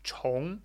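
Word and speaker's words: chong (重): Rick Engelin on chong in context. This can also be zhong. So when it's pronounced zhong, it means heavy. But in this case, it's chong, which means to repeat or, you know, start again or repeat in the sense of doing again.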